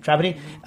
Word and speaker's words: Trapani: Pronounced Trapani or Trapani? – Trapani.